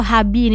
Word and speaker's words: Hubi: Hubi atau Habi ini bacanya.